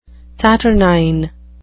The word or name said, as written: saturnine